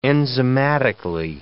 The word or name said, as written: enzymatically